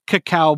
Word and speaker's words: cacao: It's pronounced cocoa when it's spelled like cocoa, but if it's spelled like dyslexic cocoa, it's cacao.